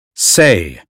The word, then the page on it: say: American English